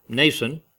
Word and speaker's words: Mnason: And brought with them one, I don't know how to say this, Mnason, M.N. Mnason, of Cyprus, an old disciple with whom we should lodge.